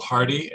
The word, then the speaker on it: Hardy: It's Bill Hardy, H-A-R-D-Y.